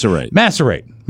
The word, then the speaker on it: macerate: You said maciate. Do you make up things? – Mallet head. – What? – It's macerate or maciate? – It's macerate. Forgive me.